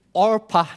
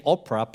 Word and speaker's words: Orpah: If by chance I said Orpah name wrong, and I said Oprah, please understand. Because those two are very similar.